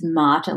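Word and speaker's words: Märtha: It's spelt Martha Louise, but I think it's Marta Louise is the oldest, and then they have Hakon, who I had to Google how to say that, and he is heir apparent.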